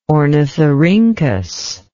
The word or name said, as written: ornithorhynchus